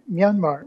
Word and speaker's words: Myanmar: Myanmar. Am I saying that right this time? – You're getting close. Just try Burma, like Burma shape. That's getting harder to find. But, Myanmar? – Yeah, Myanmar. Myanmar.